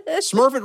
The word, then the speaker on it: Smurfit: And Smurfit, Smurfit really is spelled S-M-U-R-F-I-T.